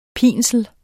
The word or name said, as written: pinsel